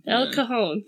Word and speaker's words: El Cajon: El Cajun? – I want to say that it's a soft J. beginning.